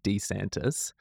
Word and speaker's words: DeSantis: That's because in his campaign videos, that's how he pronounces his name, DeSantis.